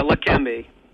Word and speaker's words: Leqembi: Leqembi? – Yeah, Leqembi.